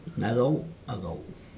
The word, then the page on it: nago ago: Aneityum